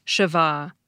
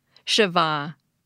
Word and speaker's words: shva: The word that gives us schwa is spelled S-H-V-A, and is properly pronounced Shiva, Shiva. A listener named Bruce Zuckerman describes it as meaning nothingness or something of no value.